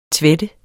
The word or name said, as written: tvætte